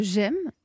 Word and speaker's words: JEM: Vous êtes la fondatrice de J'aime, ça s'écrit J-E-M.